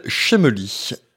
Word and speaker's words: Schemely: Alors, l'outil du jour s'appelle Shemely. – S-C-H-E-M-E-L-Y. Oh e e ok, j'allais te demander comment ça s'écrit, mais effectivement, ça s'écrit de façon bizarre.